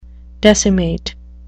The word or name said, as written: decimate